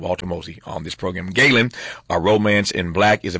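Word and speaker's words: Ghalen: It's called Galen. By the way, that's spelled G-H-A-L-E-N. G-H-A-L-E-N, if you're curious.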